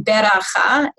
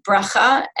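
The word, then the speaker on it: beracha (ברכה): which is the use of the shva na, for example, saying beracha instead of bracha,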